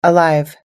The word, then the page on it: alive: American English